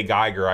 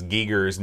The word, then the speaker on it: Giger: We say Geiger. I guess the real pronunciation is Giger's name for the alien.